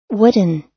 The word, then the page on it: wooden: American English